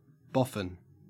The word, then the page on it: boffin: Australian English